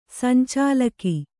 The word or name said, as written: ಸಂಚಾಲಕ